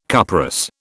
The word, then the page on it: cuprous: British English